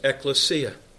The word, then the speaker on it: ekklesia: The word church in the Greek language is pronounced ekklesia.